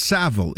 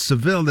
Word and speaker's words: Savile: There's a proper way, because the way that it's spelled, there's one L, so it's Savile. If it's Seville, as you had suggested incorrectly, we might add it's Seville.